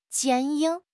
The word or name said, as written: 坚硬